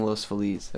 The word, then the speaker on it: Los Feliz: Because that's the correct way to say it. If you've ever taken like a week of Spanish in your life. Los Feliz, it means the happy. Feliz is happy.